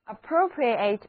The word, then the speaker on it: appropriate: 字母 O 和 A 都读它们的本音，而字母 E 的读作短的 E。单词的读音真是要拐好几个弯，一起来读两遍吧。 An appropriate example， An appropriate example 的意思就是适当的例子。